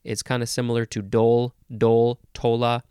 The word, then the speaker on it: dhole: The next word is, uh, well, it's pronounced dole, but it looks like d-hole. It's spelled D-H-O-L-E, dole.